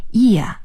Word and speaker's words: year: T-W-E-N-T-Y, 20, 20, 表示年份或者是多少多少岁，我们要说 year, Y-E-A-R, year. 这个 ear 的发音啊，跟我们说耳朵的发音是差不太多的啊、不过它的拼写呢是不一样的，应该是 y-e-a-r ear。